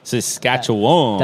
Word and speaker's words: Saskatchewan: No, but seriously, after you moved to Saskatchewan, like how... I can't even say it right. – Saskatchewan.